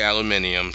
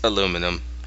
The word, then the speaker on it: aluminum: They're the weirdos that say aluminium. So I am now not shocked. And if Friday in Australia pronounces it aluminium, and she hears this, you're wrong, and you're pronouncing it weird, and it's aluminum.